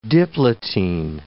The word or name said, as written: diplotene